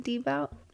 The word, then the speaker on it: debut: Is it D album something? Debut album? – Debut. – Oh, debut. That's how you say it? – Debut. – Debut album. – No, it's not the butt.